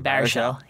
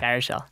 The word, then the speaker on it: Baruchel: Baruchel. Baruchel.